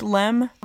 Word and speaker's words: LM: So I don't think I actually realized why the LM acronym was pronounced LEM. I thought that was the lazy way of pronouncing LM.